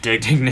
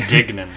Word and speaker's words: Dignan: Dignan. Dignan.